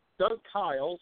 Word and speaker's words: Kiles: Doug Kiles, and he told me it's pronounced like smile.